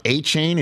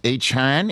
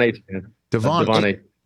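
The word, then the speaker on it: Achane: it's not A-Chain, it's A-Chan. Is that Devin's last name? Is that correct? – Yeah, Devon A-Chan.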